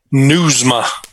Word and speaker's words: Nieusma: Nieusma, just like you're reading news to your ma.